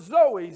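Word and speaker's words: Zoe (ζωή): Again, most of you know this, that it is a Greek word, Zoe, Z-O-E, long O and long E.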